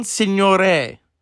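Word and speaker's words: Signoret: Is that how you say her name? I always said her name wrong. She was in that movie Dobelik. Let's see. Won an Oscar for was it Separate Tables? Okay. Very, very great French actress. – Simone Signore.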